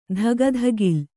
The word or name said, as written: ಧಗಧಗಿಲ್